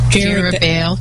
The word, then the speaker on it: Jerubbaal: Jerubbaal.